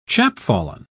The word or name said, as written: chapfallen